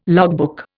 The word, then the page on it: logbook: Indian